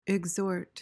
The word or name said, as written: exhort